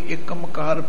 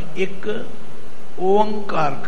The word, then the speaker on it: एक ओंकार: आम प्रेमी मैं देखा कोई एकमकार पढ़ता है, कोई एकमकार पढ़ता है, नहीं भाई, इसका उच्चारण एक ओंकार करना।